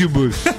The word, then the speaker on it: Incubus: ¿Qué Incubus? Incubus. Así se pronuncia.